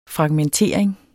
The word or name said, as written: fragmentering